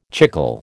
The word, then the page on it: chicle: American English